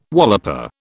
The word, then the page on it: walloper: British English